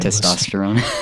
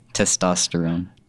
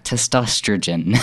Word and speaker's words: testosterone: I can never say that right. – Testosterone. – I want to add an extra like vowel in there. Say it again for me. – Testosterone.